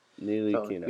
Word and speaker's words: Ntilikina: They got what was a controversial pick and Frank, how do you say his last name again? – Frank Ntilikina. So it's Ntilikina. – Ntilikina. – Your knee, Lee Kina. – Ntilikina. Thank you for that. – The T is silent.